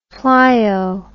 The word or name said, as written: pleio